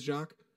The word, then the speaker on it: Jacques: I pronounced it right. It is Jacques.